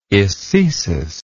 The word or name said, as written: ecesic